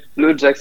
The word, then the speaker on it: LOADJAXX: LOADJAXX, c'est parfait.